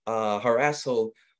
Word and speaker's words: Harasshole: Harasshole. Am I saying that right, by the way? – You are.